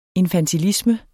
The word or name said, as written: infantilisme